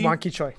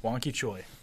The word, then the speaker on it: Wanki Choi: Wanki Choi. – Wanki Choi.